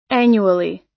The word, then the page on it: annually: American English